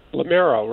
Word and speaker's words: Lamero: I hopefully I'm not driving you nuts if I mispronounce your name. It's Lamero, right? – Lamero, right. Yeah, that's okay. People mistake it. – I know. – It's a hard name to pronounce.